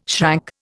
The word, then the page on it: shrank: Indian